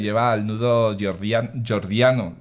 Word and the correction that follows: Gordiano: ¿lo he dicho bien? – sí. – Jordiano. – Gordiano, sí. – Gordiano, eso.